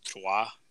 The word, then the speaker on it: Troyes: So he scored, and then Julian was mentioning over the weekend that Ike Ugbo, he's playing in Ligue 1 for, I believe it's pronounced Troyes, T-R-O-Y-E-S.